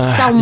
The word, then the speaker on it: salmon: It is salmon. – You're one of those people that pronounces salmon with the L.